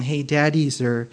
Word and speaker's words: Hadadezer: You're like, why is he saying Hadadezer? Because really, that's how you pronounce it. It's the E is long, Hadadezer, and brought them to Jerusalem.